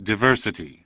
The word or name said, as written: Diversity